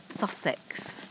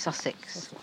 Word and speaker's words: Sussex: Sussex. Sussex. Only you pronounce it Sussex, as if it's an I at the end, I-X. It's E-X, isn't it? Sussex.